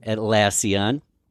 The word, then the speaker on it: Atlassian: You're a content strategy lead at Atlassian. Did I say that right? – Atlassian. Yep.